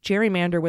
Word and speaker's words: gerrymander: Apparently, Thomas Hoffler pronounced gerrymander with a hard G to honor the former U.S. Vice President Elbridge Gerry, who pioneered the practice in Massachusetts in 1812. Gary, G-E-R-R-Y.